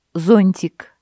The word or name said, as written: зонтик